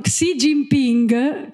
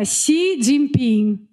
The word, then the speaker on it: Xi Jinping: no, si dice, i, i, giornalisti di solito dicono Xi Jinping, che proprio no, perché la G finale non si legge, quindi è Xi Jinping, mi raccomando.